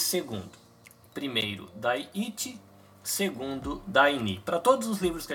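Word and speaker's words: 第二: E como é que fala segundo? Dai Ni.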